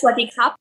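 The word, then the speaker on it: sawadee kap (สวัสดีครับ): Like, for men, it's sawadee kap. – Yeah. – Sawadee kap. Oh, I didn't say kap. So, okay. Sawadee kap.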